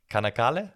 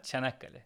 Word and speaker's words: Çanakkale: Kanakale? – Çanakkale.